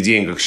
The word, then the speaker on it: деньгах: И совершенно правильно пишет Михаил Абрамович, и другие словари тоже так пишут, что в пословице «не в деньгах счастье» и в названии пьесы Островского «Бешеные деньги» в «Бешеных деньгах» здесь сохраняется ударение на первом слоге, как это было когда-то. Такой вариант был раньше, но сейчас он считается устаревшим.